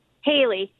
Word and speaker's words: Hayley: How do you pronounce your name, hon? – Haley. H-A-Y-L-E-Y. Haley.